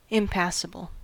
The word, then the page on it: impassible: American English